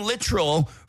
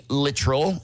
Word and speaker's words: Littrell: Brian Littrell from the Backstreet Boys, and it's pronounced Littrell, not Littrell. I know that because the record label, like, got on me for it. They're like, don't say Littrell, it's Littrell.